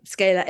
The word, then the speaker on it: Skala: So, you know, for example, they'd say the name of the station was Skala, S-K-A-L-A, Skala FM. And so we're going through the pronunciation. I'd say Skala FM. And they say, no, Skala FM.